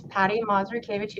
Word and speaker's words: Patty Marzukiewicz: So my name is Patty Marzukiewicz. You did a great job pronouncing my name. That's always a struggle right to begin with. It doesn't sound anything like it looks.